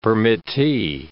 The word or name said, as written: permittee